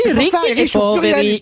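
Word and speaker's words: Ricchi e Poveri: Mais oui, bah oui, Ricky et Poveri. Et si, il y a l'accent tonique Poveri.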